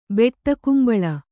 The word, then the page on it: ಬೆಟ್ಟಕುಂಬಳ: Kannada